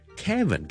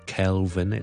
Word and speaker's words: Kevin: Kevin, K-E-V-I-N, 第一件事没有 L 的，我们没有 Keldo, not Kelvin, it's K-K-K-E-E-E 就可以了。 Kevin, Kevin, Kevin。